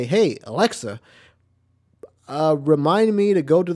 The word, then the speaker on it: Alexa: Is it Alexa or Alexia? – I think it's Alexa. – It's Alexa.